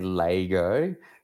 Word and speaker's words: Lego: Well, being from Adelaide, we say Lego. Don't ask me why or how on earth that came to be, but it's just an Adelaide thing, and it seems to be, I think, one of maybe two places in the world that pronounce it like that.